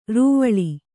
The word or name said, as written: ರೂವಿಲಿ